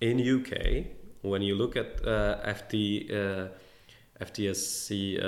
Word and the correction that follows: FTSE: uh, 500, uh, I'm butchering the FTSE. FTSE, yeah.